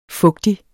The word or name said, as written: fugtig